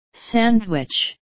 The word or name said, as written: sandwich